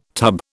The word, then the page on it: tub: British English